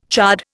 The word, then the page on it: chard: Indian